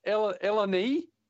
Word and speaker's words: Eleni: So this is how to pronounce it, boys. It's Eleni, E-L-E-N-I, Gluftis.